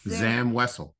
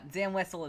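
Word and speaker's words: Zam Wesell: It's Zam Wessel. – Okay. – Is that the right pronunciation? – Yeah, it is. It's the right pronunciation. Yeah, Zam Wessel is right.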